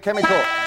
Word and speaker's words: chemical: Which consonant is silent in the word chemical? Jason. – H. – H is right, but Greg, you've won the game by just one question on $100.